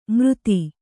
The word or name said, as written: ಮೃತಿ